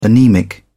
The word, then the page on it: anaemic: British English